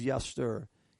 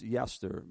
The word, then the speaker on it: yetser: It is yester. Y-E-T-S-E-R. Yester. It's pronounced yester, but it's Y-E-T-S-E-R.